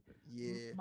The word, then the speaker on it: Vrabel: Is it Vrabel? Am I pronouncing Vrabel's that right? – Vrabel. Yeah.